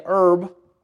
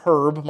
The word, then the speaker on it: herb: Verse number 12, "And the earth brought forth grass and herb." Make sure you say it right. It's not herb. My wife has helped me to understand the difference with that one.